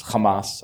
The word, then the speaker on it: Hamas: I just love the way you just pronounce Hamas. I just get it. – It's accent on the A.